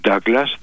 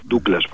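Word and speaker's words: Douglas: Κακώς τον λένε κάποιοι Ντάγκλας, δεν είναι Αμερικανός, είναι Σκοτσέζος, Ντούγκλας προφέρεται, Χάουαρτ Ντούγκλας.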